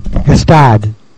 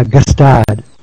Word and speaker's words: Gstaad: How do you pronounce Gstaad? – Gstaad. – You say the hard G. – Yeah, Gstaad. – Gstaad.